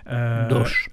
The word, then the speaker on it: Auch: Ou Doche, je ne sais pas comment on prononce exactement. – Doche, voilà.